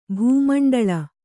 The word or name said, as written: ಭಾಮಂಡಲ